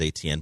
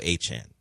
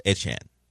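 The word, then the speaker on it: Etienne: A-chan. – Damn it. I'm reading it and it says ATN, but I know it's now A-chan. Travis A-chan.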